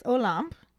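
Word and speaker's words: Olympe: Is apparently the proper pronunciation according to the YouTube video that I watched, because I was saying a limp or a limpy. I didn't really know how to say it.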